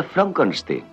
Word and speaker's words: Frankenstein: ¿Me toma el pelo? No, se pronuncia Frankenstein.